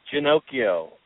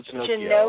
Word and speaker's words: Ginocchio: It's Ginocchio. Paul Ginocchio, like Pinocchio.